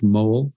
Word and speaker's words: Moule: 1, H.C.G. Mole, M-O-U-L-E, but pronounced Mole, Anglican scholar and pastor from the early, late 19th, early 20th century.